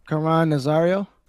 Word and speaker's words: Caron Nazario: Caron Nazario.